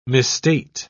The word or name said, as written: misstate